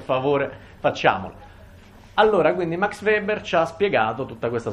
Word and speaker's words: Weber: Non c'è un sapere oggettivo, come credo di aver ormai detto e ripetuto estensivamente anche con l'aiuto del nostro simpatico Max Weber. Mi raccomando, Weber, la pronuncia è V, voi dite Volkswagen, non dite Volkswagen, non è inglese.